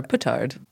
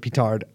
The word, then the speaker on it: petard: Uh, or petard. – Uh, yeah, that's how you pronounce, but where I'm from, you pronounce it petard.